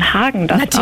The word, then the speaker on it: Häagen-Dazs: Hallo, ich bin von Häagen-Dazs und unsere Eiscreme-Marke spricht man Häagen-Dazs aus, ohne den Umlaut, der wird im Skandinavischen nicht mitgesprochen.